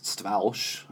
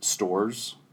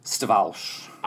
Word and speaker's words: Stawarz: The correct pronunciation, or the original pronunciation of it, is Stawarz, so I'm told. Everyone in my family kind of says it a little bit differently. – Yeah. – So some will say just stores, mm-hmm. like you're shopping at the stores. – Mm-hmm. – Some will say Stawarz. Mm-hmm. Some will say Stawarz. So it's, yeah, we all kind of say it differently.